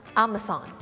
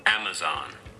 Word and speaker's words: Amazon: Amazon เขาจะออกเสียงว่ายังไงอันนี้ของ American นะคะ Amazon Amazon